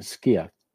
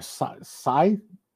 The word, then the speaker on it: scythe: Well, in the older days, they would take you don't pronounce it skiff, a scythe, I think is the way. I got several emails saying, you didn't pronounce that thing right.